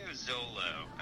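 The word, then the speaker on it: Xolo: Yeah, it's Trio Zolo. It's an Aztec word, actually, which Zolo is actually a type of dog.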